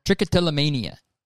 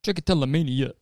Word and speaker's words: trichotillomania: Trichotillomania. – Trichotillomania.